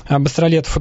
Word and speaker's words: Быстролетов: Я, к сожалению, не владею спеллингом чешским, но там его фамилия пишется через E-T-O-V. То есть, если бы он себя называл через Ё в то время, было бы другое написание. Поэтому я и в своей книге писал Быстролетов и привык так произносить. Но здесь я не придерживаюсь никаких-то жестких рамок.